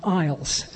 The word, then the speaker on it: IELTS: Central Library is presently offering a 30-week course in IELTS, and that's pronounced IELTS, but the initials are IELTS, and that stands for International English Language Testing System.